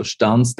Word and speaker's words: Stans: So Stans, that's pronounced correctly.